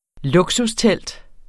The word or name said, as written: luksustelt